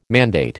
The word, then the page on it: mandate: American English